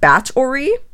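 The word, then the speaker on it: Báthory: It's spelled B-A-T-H-O-R-Y, and it's, but as far as I can tell, it's pronounced Bat-ori, like...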